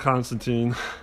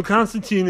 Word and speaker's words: Constantine: So technically, when the Brits created the name, the Brits pronounce it as Constantine. But us Yanks, or Americans, we pronounce it as Constantine, because that's how it's spelled, the last few letters, T-I-N-E. But British pronunciation is Tyne.